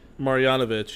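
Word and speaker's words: Marjanović: Marjanovic. – Marjanovic.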